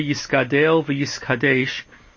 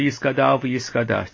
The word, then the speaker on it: yiskadel v'yiskadesh: So first of all, the Mishnah Berurah says you should say yiskadel v'yiskadesh as opposed to yiskadal v'yiskadash.